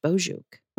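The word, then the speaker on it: Boçuk: So Bozhuk is the name. It is a fun word. It's spelled B-O-C-U-K, though, but it's pronounced Bozhuk.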